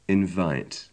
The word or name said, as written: invite